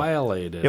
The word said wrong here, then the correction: pileated: It was a pileated woodpecker. – Pileated. Thank you. – Drawn in 1940 for Universal Studios. – I have never, not once have I pronounced that correctly.